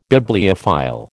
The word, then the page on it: Bibliophile: American English